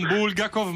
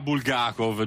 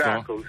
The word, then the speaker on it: Bulgakov: E diciamolo, non Bulgakov ma Bulgakov, giusto? – Bulgakov, sì, Bulgakov.